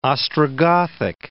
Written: Ostrogothic

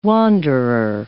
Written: wanderer

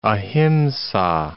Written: ahimsa

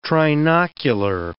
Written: trinocular

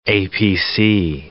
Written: APC

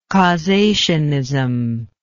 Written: causationism

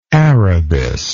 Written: arabis

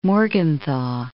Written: Morgenthau

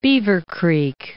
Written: Beavercreek

